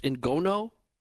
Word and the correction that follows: ngoma: the drum? – Ngoma.